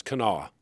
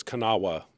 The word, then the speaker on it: Kanawha: I learned yet on my first day here how to pronounce Kanawha. Now, you know, if someone says Kanawha, they don't know where they are.